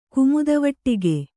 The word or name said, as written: ಕುಮುದವಟ್ಟಿಗೆ